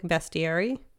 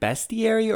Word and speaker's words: bestiary: Is it bestiary or bestiary? – I guess technically it's bestiary. – Okay. So I know it's spelled bestiary, but I've always heard it said bestiary. – Yeah, I think it's supposed to be bestiary.